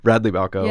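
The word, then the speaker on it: Radley Balko: Radley Balco. Yeah. Is that how I'm pronouncing it? I never knew how to pronounce it. – That's right.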